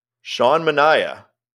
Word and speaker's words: Sean Manaea: Sean Manaea. – Hey, you said it right.